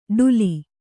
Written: ಢುಲಿ

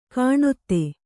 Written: ಕಾಣಒತ್ತೆ

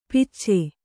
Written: ಪಿಚ್ಚೆ

